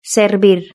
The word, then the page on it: servir: Spain Spanish